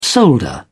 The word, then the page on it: solder: British English